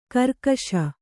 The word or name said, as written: ಖರ್ಗಸ